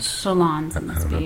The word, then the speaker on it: Solondz: Solondz, yeah.